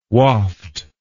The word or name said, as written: Wafd